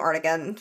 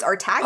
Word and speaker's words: Artagan: I also called him Artigan for ages. It was only this campaign where I was like, oh, it's Artagan? – Oh, Artagan?